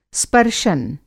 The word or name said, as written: स्पर्शन